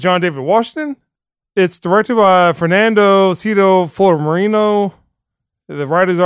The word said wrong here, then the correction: Filomarino: Cito Filomarino.